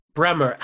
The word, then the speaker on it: Brehmer: Brehmer.